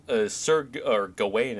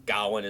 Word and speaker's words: Gawain: But, you know, Gawain is the common. I don't know if it's the English pronunciation. It's definitely the American one. But I did see, like, looking it up that, like, Gawain is probably the more correct pronunciation, although both, you know, both are acceptable.